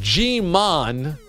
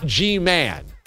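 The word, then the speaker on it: Ji-man: And I'm pretty sure pronounced G-Mon, not G-Man.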